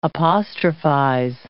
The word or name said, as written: apostrophize